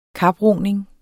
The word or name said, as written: kaproning